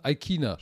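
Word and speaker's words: Ikhine: Sie haben Tannehill, sie haben Derek Handy, sie haben Robert Woods, AJ Brown, Westbrook Aikina oder Aikina, Aikina spricht man den aus.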